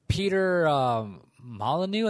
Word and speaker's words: Molyneux: But anyways, this fool, Peter Molyneux, I think his name is. It's spelt weird with an X in it somewhere, but that shit's like silent, but I'm pretty sure that's how you pronounce it.